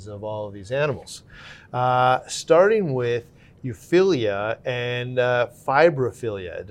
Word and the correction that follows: Fimbriophilia: Did I pronounce that? Or Fimbriophilia. Fimbriophilia.